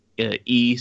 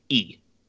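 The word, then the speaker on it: E (이): His name would have been, actually been Lee Sung-mun. We say Lee, but that name is actually more pronounced. It's literally just E. It's just the letter E. That's how that name is actually pronounced.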